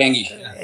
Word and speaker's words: Enge: And I actually just saw an article from Eric Eng. Uh, is that how you pronounce it? Eric Eng? – Yeah, um, Eric Enge. Enge.